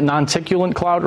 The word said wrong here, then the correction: noctilucent: right? – Noctilucent? – Non-ticulant cloud? Noctilucent. Yeah, yeah. I've mispronounced it like 1,000 times.